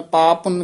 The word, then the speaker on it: पाप पुन: श्लोक महला तीजा कर्म धर्म साब बंदना पाप पुन संबंध इसी तरह ही उच्चारण करना है कि कठे कह दें पाप पुन कह जन्दे हन जिदे नाल एक पप्पा रह जन्दा है दोवे पप्पे शुद्ध बोलने पाप पुन संबंध